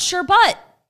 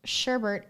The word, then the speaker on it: sherbet: It's called sherbet. – With an additional R in the second syllable and pronounced sherbert is less commonly used.